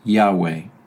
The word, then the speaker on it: Yahweh: God's personal name and most frequent designation in Scripture is the Hebrew word YHWH, which is usually pronounced Yahweh.